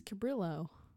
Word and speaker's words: Cabrillo: According to online pronunciation lady, it's Cabrillo. So we don't pronounce it. – Cabrillo. – Pronounce it like Spanish.